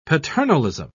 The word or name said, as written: paternalism